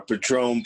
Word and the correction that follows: Patreon: page. Yes, the Patron page. – Patreon. – Yeah, Patreon. I can never say it right.